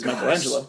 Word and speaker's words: Michelangelo: But we also got Homer refers to Michael Michelangelo, who pronounced correctly is Michelangelo.